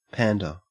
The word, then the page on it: pander: Australian English